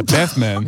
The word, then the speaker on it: Bathmen: Ja, is dat hoe je het moet uitspreken? – Ik twijfel een beetje of dat nou klopt. – Weet jullie het? – Anders heb ik het al in de vorige podcast ook verkeerd uitgesproken. Wat zijn de opties? – Of wat klopt? – Bathmen. – Oh, ik durf dit niet te zeggen. Ik zie het. Ik denk Bathmen. – Ja, Bathmen.